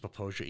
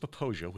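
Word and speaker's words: Popo Agie: pronounced Popoja, even though it does not look like that. If you see the word written out, it looks like Popoagi or all kinds of crazy things. But it's pronounced Popoja, which is a Crow word, a Crow Indian word, and it means, depending on who you talk to, either gurgling river, grass river, headwater river.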